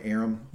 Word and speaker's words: Aram: Am I pronouncing his right name right? Aram? – Yeah.